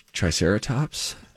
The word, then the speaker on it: triceratops: tricerat, triceraptis. – She said triceraptis. – Triceraptis. – I said, do you think it's triceratops? – and I said yes.